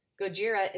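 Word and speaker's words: Gojira (ゴジラ): Gojira by Brian Farabee. This 9-foot polychrome steel sculpture is inspired by the mythical radioactive monster from the 1954 Japanese movie. Gojira is the original Japanese pronunciation of Godzilla.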